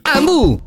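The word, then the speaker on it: Hambu: Ambu si scrive con l'H.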